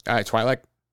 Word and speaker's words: Twi'lek: Is it pronounced Tweak? – Twi'lek. Or Twi'lek, maybe.